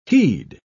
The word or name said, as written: he'd